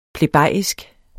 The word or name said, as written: plebejisk